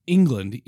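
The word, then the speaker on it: Englund: His name is Tom England. E-N-G, like it's spelled as Robert England. – Right. – It's kind of the same way.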